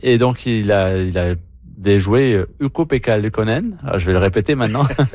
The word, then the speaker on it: Ukko-Pekka Luukkonen: Newhook, hier, a déjoué Ukko-Pekka Luukkonen.